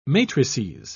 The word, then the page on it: matrices: British English